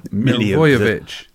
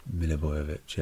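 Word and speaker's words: Milivojević: So Wan-Bissaka, Milivojevic, how do you say his name? – Have another go. – Milivojevic. – No, Milivojevic. – Again. – Milivojevic. – Milivojevic. – Milivojevic. – Milivojevic, yeah. – But quicker.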